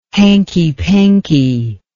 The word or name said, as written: hankypanky